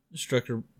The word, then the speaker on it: Strucker: Strucker brand watch, and fill us in on Strucker, Chris.